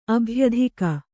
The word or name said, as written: ಅಭ್ಯಧಿಕ